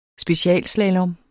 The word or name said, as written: specialslalom